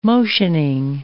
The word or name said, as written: motioning